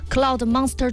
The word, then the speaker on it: Cloud Monster: 而且今天又给帮大家重新谈到了新品，就是昂跑他们家的这个 Cloud Monster 2 啊，不知道这个发音对不对啊？ – 是的，非常之正确。